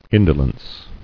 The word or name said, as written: Indolence